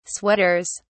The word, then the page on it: sweaters: British English